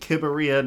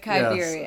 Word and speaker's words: Kibberia: So we're going to talk a little bit about Kibberia, and that's how you say it, Kibberia, not... – Yes. – How are other people saying it? – Kibberia. Like New Iberia, Kibberia. – Yes. So it's Kibberia.